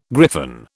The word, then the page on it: griffon: British English